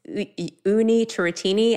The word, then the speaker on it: Unni Turrettini: And my name, so it's Unni Turrettini.